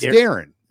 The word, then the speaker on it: Darin: It's spelled D-A-R-I-N. – It's not Darian, though. It's not. It's Darren. He goes by Darren.